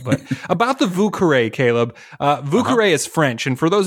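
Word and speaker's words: Vieux Carré: It's V-I-E-U-X-C-A-R-R-E, Vucaré, Vucaré.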